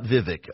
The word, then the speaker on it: Vivek: So his team said it's a hard E, so it's V, not V, like I've been saying, Vivek. It's not Vivek. It rhymes with cake, so Vivek Ramaswamy.